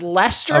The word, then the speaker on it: Leicester: Leicester. It's Leicester. – Of course, it's like a Worcester situation.